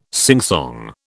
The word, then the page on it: singsong: British English